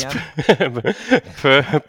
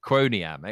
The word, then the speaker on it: Quoniam: Did I pronounce Quoniam right? Is it Quoniam? – Yes. – Quoniam? – Perfect pronunciation, yeah. – It's Quoniam, excellent.